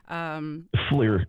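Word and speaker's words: FLIR: Fleur, yeah, forward-looking infrared.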